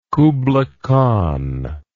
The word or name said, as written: kubla khan